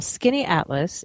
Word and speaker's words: Skaneateles: Skaneateles in New York, which is near Syracuse. And I learned how to pronounce it when I was there because it's not pronounced the way it's spelled.